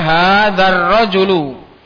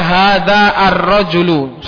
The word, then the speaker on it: hadzar rajulu: Contohnya hadzar rajulu. Di situ alifnya enggak ada. Ditulis tapi enggak terucap. Berbeda dengan dimulai ar-rajulu, dibaca ar-rajulu. Tapi kalau disambung di tengah, enggak boleh dibaca hadza ar-rajulu, salah.